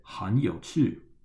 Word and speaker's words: 很有趣: Remember, 很 is in the third tone, and then 有 at the start of 有趣 is also in the third tone. That makes it a double third tone exception rule, so we pronounce it 很有趣.